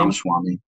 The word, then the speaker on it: Ramaswamy: And how do you say that guy's name? Ramaswamy? Is that his name? – Ramaswamy. Vivek Ramaswamy.